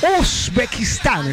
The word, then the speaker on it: Uzbekistán: Pues, a ver, Katy, ¿cómo sería Uzbekistán? – Así es.